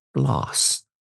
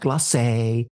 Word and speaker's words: Gläce: I will make it so that those that know understand that it's pronounced gloss, like lip gloss, and not glossé or glossé or whatever, right?